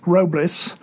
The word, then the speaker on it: Robles: We're here today with a new acquisition for Red Hawk Publications, poet Tony Robles.